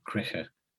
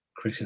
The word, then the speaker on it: Krige: But they're probably likely also to find it if they Googled my name, Dr. Anton Kriche. Kriche is spelled K-R-I-G-E.